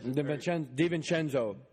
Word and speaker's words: DiVincenzo: Nova was 20 points better than everybody all tournament, and that white Italian dude, DeVinca, how do you say his name? – Dante DiVincenzo.